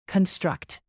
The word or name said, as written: construct